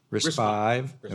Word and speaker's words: RISC-V: RISC-V? RISC-V.